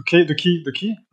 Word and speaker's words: quay: The key? How do you pronounce this? – Key. Yeah, the key.